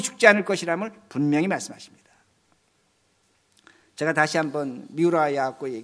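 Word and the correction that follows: 미우라 아야코 (三浦綾子): 얘기, 미우라 발음이 안 되는데 아야코 얘기를 하겠습니다. 미우라 아야코가 그 책을 읽었는데,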